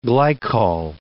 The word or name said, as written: glycol